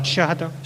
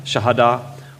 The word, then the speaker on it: shahada: shahada? – Shahada. – Shahada, or confession, which faithful Muslims around the world declare daily.